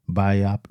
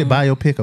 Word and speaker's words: biopic: One being the Otis Redding biopic. Mm. Do you say biopic or biopic? – Biopic? – Huh? – Biopic? – That's how you say it? – Mm.